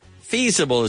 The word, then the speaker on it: feasible: So feasible, like feasible. Feasible is the pronunciation.